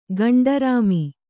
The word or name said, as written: ಗಂಡರಾಮಿ